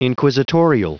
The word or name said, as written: inquisitorial